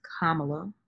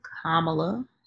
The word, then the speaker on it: Kamala: It is Kamala, not Kamala, not Kamala, it is Kamala Harris, number one.